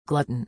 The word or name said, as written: glutton